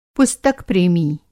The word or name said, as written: पुस्तक-प्रेमी